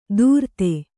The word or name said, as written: ದೂರ್ತೆ